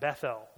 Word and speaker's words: Bethel: at Bethel or Beth-El, depending upon how you want to pronounce that.